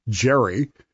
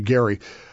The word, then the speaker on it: Gerry: We mispronounce his name regularly as Elbridge Gerry, and he is the guy for whom gerrymandering is named. – Okay. – But it's actually Gerry.